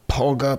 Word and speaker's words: Pauga: His opponent his opponent was Paga. How do you pronounce it? I want to make sure I'm saying it right. Paga, right? – I would call him Zach Paga. Paga. So yeah. P-A-U-G-A.